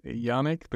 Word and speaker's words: Yannick: Now, most Americans, like, pronounce my name as Yannick because Yannick. Nick is, like, very familiar name in America, but yeah, it's a French name.